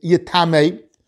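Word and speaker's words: yitamei (יטמא): v'kol mashka shi shos v'kol kla yitma. The Torah should have said the word yitma, which we indeed are pronouncing yitamei, and teaching us that it gives off tumah.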